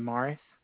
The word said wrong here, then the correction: myrrh: I may be pronouncing that wrong, it's spelled M-Y-R-R-H. – Myrrh.